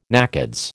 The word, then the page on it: NACODS: American English